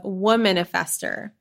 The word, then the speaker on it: Womanifester: Today's guest on the pod is Katie Jones of Womanifester. I hope I keep saying that right. It's Womanifester, like manifester, but she's all about the divine feminine, so it's Womanifester.